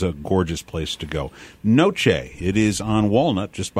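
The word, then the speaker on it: noce: And it's pronounced Noche, which also sounds like the Spanish word for night.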